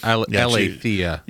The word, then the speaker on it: aletheia: We're going to see aletheia, is that the word? – Aletheia. – Aletheia. – You got to put your emphasis on the right syllable, as we like to say.